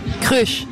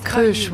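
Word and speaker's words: Kreusch: Comment on prononce votre nom, Sylvie Crush? – Crush. – Yeah. – Crush. – It's just Crush, ouais. Allez, ça se prononce comme ça s'écrit. Pour nous, en tout cas, c'est comme ça qu'on le dit. Ça peut être différent dans chaque pays, mais même en Belgique, ils ont tendance à compliquer les choses. Mais de nombreuses personnes prononcent crush, et je préfère vraiment ça.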